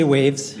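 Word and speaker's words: WAVs: are we pronouncing it Waves or Wabs? – We say Waves. – You say Waves.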